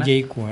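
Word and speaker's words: Jaecoo: Los chinos lo pronuncian Jaecu, ¿eh? Y también aquí es Bain, lo pronuncia Jaecu.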